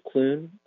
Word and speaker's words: Clune: My name is Peter Clune. My last name is spelled C-L-U-N-E.